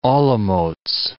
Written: Olomouc

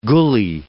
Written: gully

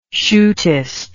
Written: shootist

